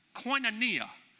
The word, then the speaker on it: koinonia: The word communion in the Greek word is pronounced koinonia.